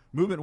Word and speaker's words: MVMT: Movement Watches, again spelled MVMT but pronounced Movement, was founded on the belief that style shouldn't break the bank.